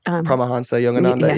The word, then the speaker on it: Paramahansa Yogananda: Paramahansa Yogananda.